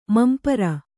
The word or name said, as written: ಮಂಪರ